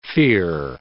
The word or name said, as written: fere